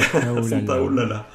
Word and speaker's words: Santaolalla: Euh, il s'agit d'une musique euh, composée par Gustavo Santaolalla, pardon. – Ah, c'est prononcer. Ouais, Santaolalla.